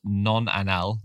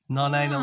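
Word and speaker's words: nonanal: Uh, and one of them is called I think it's pronounced non-anal, non-anal, but it's spelled non-anal. non-anal.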